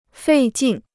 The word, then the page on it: 费劲: fèi jìn